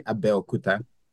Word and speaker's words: Abeokuta: Yeah, so the pronunciation is actually Abeokuta. Yeah, but it's okay. But you did a pretty good job. You're close. So it's Abeokuta 52, and Abeokuta is the capital of my home state back in Nigeria, which is Ogun State.